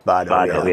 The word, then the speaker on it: Spado: Is that the way I pronounce that, Spado, or is it Spado? – The correct pronunciation is Spado. – Spado, yeah.